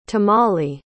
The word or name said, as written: tamali